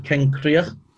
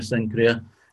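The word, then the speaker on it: Cenchreae: And he says is it Kenkria? That's the way you pronounce it, Kenkria, Senkria.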